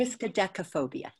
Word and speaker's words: Triskaidekaphobia: We're looking at Slow Death and Taxes, Crocodile Suitcase, Belief, The Inheritance, Triskaidekaphobia. Did I say that correctly? – Triskaidekaphobia. Yes, very, very, very much.